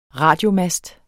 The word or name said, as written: radiomast